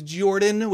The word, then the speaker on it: Jorphdan: My name is Jordan, with a silent PH in the middle, and I am joined always by my wonderful co-host, Sir Lucian, over there at Sir Lucian Gaming.